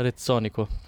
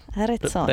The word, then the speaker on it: Rezzonico: Äh, Rezzonico. – Rezzonico.